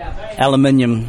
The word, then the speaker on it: aluminium: It's an aluminum, or how do you say it? – Aluminium. – Yes, exactly.